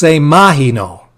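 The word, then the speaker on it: semaino (σημαίνω): The Greek word, see if I can get this, is pronounced semaino. That's how you say signified in Greek, semaino.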